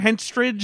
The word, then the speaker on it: Henstridge: Henstridge? – Henstridge.